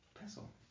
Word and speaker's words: pestle: Pestle. – No T pronounced.